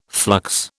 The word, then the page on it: flux: British English